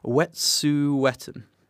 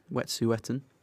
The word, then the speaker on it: Wet'suwet'en: Wet'suwet'en. Wet'suwet'en.